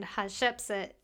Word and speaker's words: Hatshepsut: Oh, by the way, her name is spelled, just in case you want to Google it, H A T S H E P S U T.